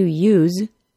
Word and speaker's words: use: To use is pronounced with a Z sound. A use is pronounced with an S sound.